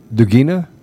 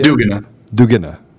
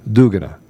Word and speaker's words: Dugina: I mean, I'm gonna start by asking you some questions related to Dugina. Daria. – Dugina. – I said it wrong. Yeah. I wrote it down, and I still said it wrong. Dugina.